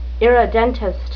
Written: irredentist

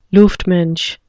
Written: luftmensch

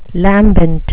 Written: lambent

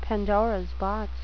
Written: pandoras box

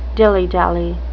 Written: dilly-dally

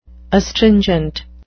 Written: astringent

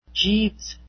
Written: jeeves

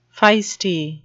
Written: feisty

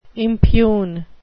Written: impugn

